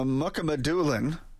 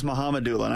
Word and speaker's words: Mukhamadulin: And as far as Shakir Mukhamadulin, I always thought it was Mukhamadulin. I thought the K was silent, but we'll get the official pronouncer from him. Anyway, Mukhamadulin, as I'm going to call him until otherwise noted, Bowman was talking about him and what he can bring to the fold.